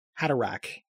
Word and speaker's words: Haderach: I have always had that CH as a hard sound. – That makes way more sense. Yeah. Yeah. Huh. Haderach.